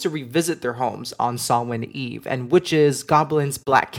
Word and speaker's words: Samhain: And just a note, if you've seen this word, you might have pronounced it Samhain before. Not going to lie, I've read it and used to pronounce it that way until I heard it said, and it is Samhain.